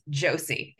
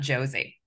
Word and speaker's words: Josie: My name is Josie. It's not Josie. – Oh my gosh. – I know. So many people pronounce it wrong. Name? People who have known me for 20 years pronounce it wrong. – Oh my God. – Yeah, it's got a soft S.